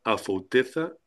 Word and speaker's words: Afouteza: Sabela Gandulfes, que ha publicado el poemario, déjame ver si lo digo bien ahora, Afouteza y Certeza, ¿correcto? – Muy bien, lo has dicho perfecto.